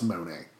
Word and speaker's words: Money: Yes, I know it's spelled money, but it's pronounced Monet.